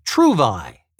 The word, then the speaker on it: Trouvaille: Today's word of the day is Trouvaille. It's spelled T R O U V A I L L E.